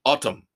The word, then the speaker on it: ATUM: A rock opera in three acts called Autumn. – A-T-U-M. – Yep. Billy Corgan swears it's pronounced Autumn.